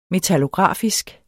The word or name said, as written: metallografisk